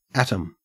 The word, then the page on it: atom: Australian English